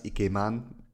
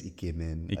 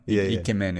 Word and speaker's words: ikemen: But I know it is ikemen. I always say it wrong because it's kind of in my head. – don't say ikeman. Ikemen is correct.